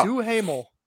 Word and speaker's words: Duhamel: Duhamel.